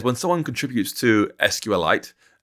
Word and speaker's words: SQLite: Richard Hipp, the creator of SQLite, who told me, by the way, it's a fun fact for listeners, that SQLite he pronounces as a mineral. He pronounces it as SQLite. – What? – Like, you know, carbonite or similar.